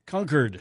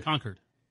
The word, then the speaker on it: Concord: while North Carolinians pronounce the second syllable so it rhymes like board. New Hampshire, Massachusetts, and California are among those who favor something closer to the words of Julius Caesar Concord. – Concord. C U R D.